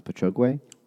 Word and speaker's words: Patchogue: I think it's pronounced Patchogue.